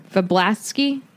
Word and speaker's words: Vablatsky: Vablatsky? – Yeah. – Vablatsky. So I looked her up because I knew like Sybil Trelawney has like a Cassandra in her family, but the last name is not Vablatsky.